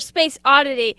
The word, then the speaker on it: Space Oddity: That was David Bowie's Space Odyssey. You're listening, or Space Oddity. I never realized until now that I have been mispronouncing that my entire life.